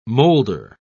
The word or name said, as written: molder